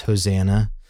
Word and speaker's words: Hosanna: And these phrases are really connected to a Greek word that's going to come later, that we would pronounce Hosanna, which Christ is greeted when he enters into Jerusalem. – The triumphal entry. – Hosanna.